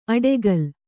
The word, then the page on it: ಅಡೆಗಲ್: aḍegal; Kannada